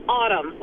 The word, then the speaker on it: Autumn: So, my name is Autumn, like the season.